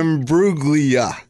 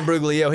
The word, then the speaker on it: Imbruglia: They're both missing it by one syllable. – Guys, it's Natalie That's what I said. – Imbruglia. – That's correct. – What did I say? Imbruglio? – You said Natalie Imbruglio. He said Natalia. Natalie.